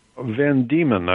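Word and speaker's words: Van Deman: Ralph Vandeman, is that how you say his name? – Van Diemen, I think. – Oh, that makes even more sense.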